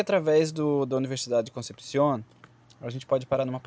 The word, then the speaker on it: Concepción: Concepción pronuncia-se com, com K, em espanhol na lá, é uma comuna e cidade chilena.